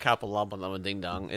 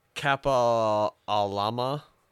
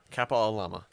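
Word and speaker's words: Kapālama: It's not Kappa Lama Lama Ding Dong. It's Kappa Alama. – Sure. Yeah, Kappa Alama.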